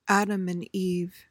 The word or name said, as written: adam and eve